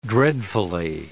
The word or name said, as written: dreadfully